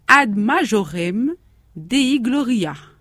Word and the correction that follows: gloriam: ». J'ai bien prononcé, Maria ? – Oui. – Gloriam, oui. Qui signifie « Tout pour la plus grande gloire de Dieu ».